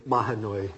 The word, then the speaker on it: Mahanoy: The first case this morning is number 19-1842, BL, the Mahanoy Area School District. I don't know if I'm pronouncing the school district's name correctly. – Mahanoy, Your Honor.